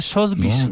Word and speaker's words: Sotheby's: Es Sotheby's, no, ¿cierto? – Sí, decirle así. Ve, Sotheby's.